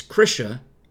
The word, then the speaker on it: Krisha: It's Krisha rhymes with Trisha. Like attrition.